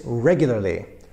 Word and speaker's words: regularly: So those four syllables in regularly, regularly, kind of get the wrong stress or a little bit too much Polish vowel use there. It's regularly. Regularly, not regularly, but regularly. Number 6 and number 5, I'm going to talk about those in the same little talk here.